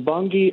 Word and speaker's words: Bunge: First of all, how great it is that you pronounce it correctly. I always have to pronounce it correct. People say it's not Bunge, but obviously we're.